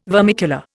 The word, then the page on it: vermicular: Indian